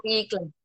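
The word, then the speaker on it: Pikler: Eu estou falando certo, né? Pickler Brasil. – Ah, Pickler, isso.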